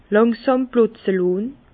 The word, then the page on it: làngsàm plotze lohn: Bas-Rhin; Herrlisheim